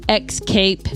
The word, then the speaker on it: escape: Mary Kay's talking about escape. Not escape. – Escape.